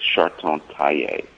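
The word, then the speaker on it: Chartogne-Taillat: And my favorite producer right now is Chartons Taillet.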